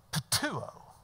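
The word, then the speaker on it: Patuo (πτύω): Patuo. Patuo. Do you know what patuo means? To spit. Patuo. Patuo. I just love the word. Patuo. It's like an onomatopoeia. Patuo. I'm spitting just saying it. Patuo.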